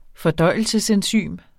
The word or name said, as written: fordøjelsesenzym